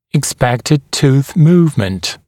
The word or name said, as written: expected tooth movement